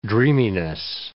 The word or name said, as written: dreaminess